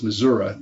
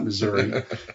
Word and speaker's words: Missouri: I was born in my parents said the correct pronunciation is Missouri, not Missouri.